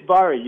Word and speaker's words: Bari: Spell your name like the Italian city of Bari, but it's pronounced Barry. – It's Bari. You got it right.